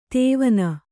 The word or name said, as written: ತವನ